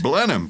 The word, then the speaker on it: Blenheim: Blenheim, please. That's the pronunciation.